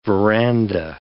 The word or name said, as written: verandah